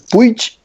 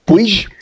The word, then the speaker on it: Puig: Eu nunca acerto a pronúncia do, do, do chefe da Honda, do Alberto. Eu não sei se é Puig. Puig. Puig. A gente, Puig. – É um, é um nome meio esquisito, mas é, se, se, diz Alberto Pucci. Não, não, se pronuncia como se lê.